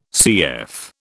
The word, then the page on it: CF: British English